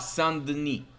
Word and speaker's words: Saint Denis: The thing about like Denise is that, and I don't know if I'm saying his name right. – I Denis Benoit Saint Denis.